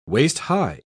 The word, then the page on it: waist-high: British English